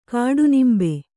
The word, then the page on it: ಕಾಡನಿಂಬೆ: kāḍanimbe